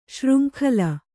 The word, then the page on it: ಶೃಂಖಲೆ: śřnkhale